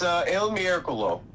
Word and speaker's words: Il Miracolo: All right, so the five horse, is it two Miracolo? Is that like two in Roman numerals, or is it IL? – It's Il Miracolo.